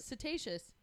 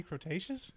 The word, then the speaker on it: Cretaceous: And then she kept not saying Cretaceous right. – How was she pronouncing it? – Cetaceous. – Holy shit, she was trying to say Cretaceous? – Yeah. Because she says it twice, and I'm like, that's not how you say that word.